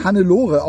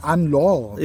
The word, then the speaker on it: Anne-Laure: Ich weiß nicht, es ist eine Französin, sie heißt bestimmt nicht Hannelore, auch wenn es so geschrieben ist. Ich würde mal sagen Anne-Laure. – Ja, genau. – Wird sie wahrscheinlich ausgesprochen. – Dann Carré. Also C-A-Y-R-E geschrieben.